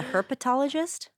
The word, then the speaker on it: herpetologist: It's a herpetologist? – Herpetologist. – Wow. – I don't know if I'm pronouncing that correctly, but it's spelled like herpes, like herpetologist.